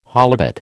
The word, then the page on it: holibut: American English